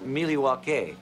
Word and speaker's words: Milwaukee: Actually, it's pronounced Miliwake, which is Algonquin for the good land.